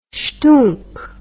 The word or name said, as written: schtoonk